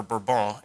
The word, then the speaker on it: Bourbon: So for example, in the country of in the 1500s, the 15 and 1600s, the Bourbon royal family, or Bourbon, if you're a Francophile and want it pronounced correctly, but the Bourbon royal family has consolidated governmental power within their family, and they are reigning as the monarchs of France.